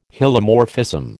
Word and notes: Hylomorphism: American English

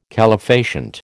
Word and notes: calefacient: American English

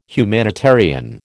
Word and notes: humanitarian: American English

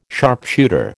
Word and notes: sharpshooter: American English

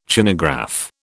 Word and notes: Chinagraph: British English

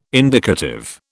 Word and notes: indicative: British English

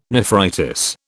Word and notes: nephritis: British English

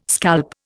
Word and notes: scalp: Indian

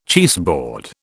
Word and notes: cheeseboard: British English

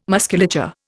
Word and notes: musculature: Indian

